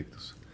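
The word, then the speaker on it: Ichthys: Yo tenía un pescadito que decía Ixoye o Ictus, que se pronuncia Ictus.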